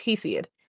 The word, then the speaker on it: Hesiod: I'm an idiot. It's Hesiod. – Oh, okay. – It's just spelled weird, and my American brain wants to pronounce it differently.